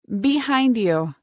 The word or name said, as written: Behind you